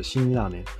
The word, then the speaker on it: 辛ラーメン: 辛いの一文字だけ書いて、新と読むんですけど、辛ラーメン。